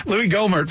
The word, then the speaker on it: Gohmert: And I pronounce that right. It's not Gohmert. It's Louis Gohmert from Texas is talking right now.